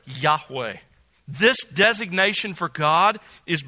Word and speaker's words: Yahweh: It's probably pronounced, we don't have the vowel pointings preserved in the manuscripts that have been passed down to us, only the consonants, but it was probably pronounced something like Yahweh, Yahweh.